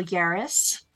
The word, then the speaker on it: Yahraes: Today, we have back again, once more, Daniel Yahraes. Well, oh, that's me. Rhymes with Paris.